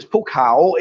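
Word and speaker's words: Phu Khao (ภูเขา): Phu Khao Thai. Mountain. Khao. Oh, Phu Khao. And listen to how they say it. Say that again. And remember the tones, it's Pukau, it's rising cow, not Pukau.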